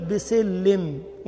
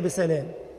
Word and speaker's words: بسلام: مثال آخر، طالب في الجامعة كان يقرأ وقيل يا نوح اهبط بسلم وهي بسلام، وفي الجامعة إنه ما نطقش الألف، ما خدش على إيد العلماء.